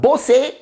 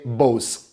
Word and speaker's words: Bose: acel brand faimos pe care românii mai puțin titrați îl pronunță Bose, dar cu aceeași reverență pe care le regăsești și la conesorii care știu să pronunțe americanește Bose.